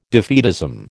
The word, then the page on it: defeatism: American English